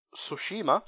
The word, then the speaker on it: Tsushima: I'm going to butcher this pronunciation, but Ghost of Tsushima.